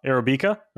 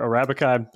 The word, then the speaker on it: Arabica: called uh, Arabica or Arabica. I'm not exactly sure if I'm saying that right, but is that correct? Yeah, they're both they're both fine pronunciations.